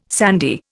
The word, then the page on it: sandy: Indian